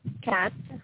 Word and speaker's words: Kat: Kat. My name is Kat. – Will you spell that, please? – K-A-T. – K-A-T.